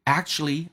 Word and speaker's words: actually: Actually, I'm feeling quite ill today. Actually, 事实上这个单词呢，在口语化当中一般 k 跟 ch 两个辅音在一起就用发后面一个，所以 k 可以去掉变成 actually，以 u 的组合通常可以把 u 去掉，所以这个单词去掉 k 去掉 u 叫做 actuallyI'm feeling quite ill today.